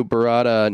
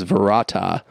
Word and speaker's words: Barada: Contrary to popular belief, Ash does not say the words Klaatu Barada Nikto, a reputed homage to the day the earth stood still, 1951, but a slight variant in which he pronounces the second word as Varata instead of Barada.